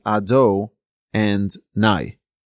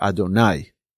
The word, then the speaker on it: Adonai: Hashem's name should only be pronounced as Ado and Nai. The proper emphasis should be on the latter part of not Adonai or Adonai, but rather Adonai, okay, on the latter part of the word.